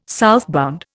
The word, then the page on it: southbound: Indian